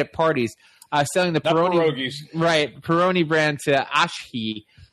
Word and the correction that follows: Asahi: Ashi? Can you pronounce that one? – Didn't even try. – Asahi. Yeah, there you go.